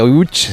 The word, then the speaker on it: Łódź: Aunque se pronuncia Łódź, se escribe Lodz, por si alguien lo busca Lodz, en Google.